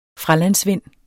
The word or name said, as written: fralandsvind